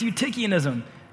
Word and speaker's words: Eutychianism: It rhymes with Schmutikianism. If somebody say Eutychianism, you're right. It's Eutychianism.